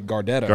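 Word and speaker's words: Gardetto's: I pronounce it Gardados, but some people say Gardettos.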